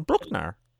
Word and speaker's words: Bruckner: Today is September 20th, Wednesday, and Tori is performing in Linz, Austria at Bruckner House, a venue named after the composer Anton Bruckner.